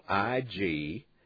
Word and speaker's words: Ignacio: And on the next line, you'll put the town we live near, capital IGNACIO. That's pronounced Ignacio, comma, then capital C, capital O, period, and then 81137 is the zip code.